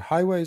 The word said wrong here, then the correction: Huawei: is it Huawei? Huawei. Okay,